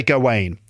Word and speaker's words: Gawain: People seem to disagree about how to pronounce the name. I've heard Gawain, Gawain, Gawain. I say Gawain.